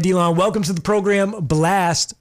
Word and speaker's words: Blxst: Yeah, so it's pronounced Blxst. I know it's spelled Blxst, but really it's just to be unique when you search it up on some brand, just thinking about branding wise.